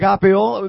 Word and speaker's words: agapeo: The only reason I can say, God, I agape you, or it's pronounced agapeo, the only reason I agapeo you is because you agapeo me first.